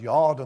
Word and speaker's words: yod: The word jot refers to the smallest Hebrew letter, and it's pronounced yod in the Old Testament.